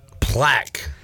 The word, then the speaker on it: Plack: His name is Plaque. – Plaque? – It's his last name. – Plaque. – He's a Delaware All CAA. – How do you spell that? – P-L-A-C-K. – Plaque.